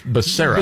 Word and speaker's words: Becerra: Baccaria, so Baccaria. I have to go, Becerra. And I have to tell myself like five times. – But it's not Chuh, it's Becerra.